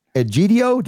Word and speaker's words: Egidio: Egidio, did I say that right? – You said it right.